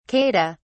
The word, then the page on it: kata: British English